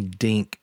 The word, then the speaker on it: Dink: I was MC Dink. That rhymes with think.